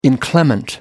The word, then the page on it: inclement: American English